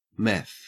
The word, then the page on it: meth: Australian English